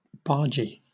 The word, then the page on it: bargee: British English